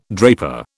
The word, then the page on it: draper: British English